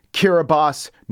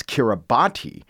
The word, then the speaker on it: Kiribati: You know, a lot of people think it's Kiribati because that's the way it's spelled, but it's actually Kiribati. And that's item number one. It's actually pronounced Kiribati.